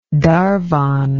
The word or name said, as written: Darvon